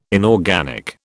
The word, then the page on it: inorganic: British English